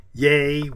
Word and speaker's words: yea: Some of those would cause confusion, like Y-A-Y. Yay is very different from yeah. – Right. And I pronounced Y-E-A as yay. – Yay, Y-E-A. – Like yay or nay.